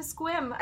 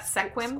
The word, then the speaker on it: Sequim: And he heads on back to Squim. I think that's how you say it, right? Squim, not Sequim.